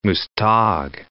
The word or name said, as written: Muztag